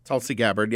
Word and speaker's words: Tulsi Gabbard: I'm going to butcher her name, but how do you, is it Tulsi Gabbard? – Tulsi Gabbard, yeah.